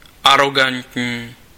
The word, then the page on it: arrogant: /ˈæɹəɡənt/